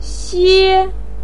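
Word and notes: xue: tone 1

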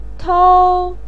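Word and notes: tou: tone 1

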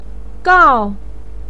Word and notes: gao: 4

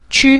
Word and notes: qu: qu5

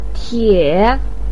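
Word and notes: tie: tone 3

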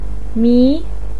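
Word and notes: mi: tone 2

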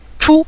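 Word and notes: chu: tone 5

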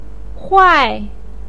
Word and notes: huai4: pinyin

